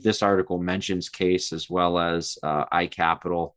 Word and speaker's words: CAIS: And we say CAIS, right, Jimmy? – It's pronounced CAIS, but it is capital CAIS.